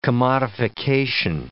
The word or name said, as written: commodification